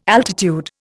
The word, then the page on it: altitude: Indian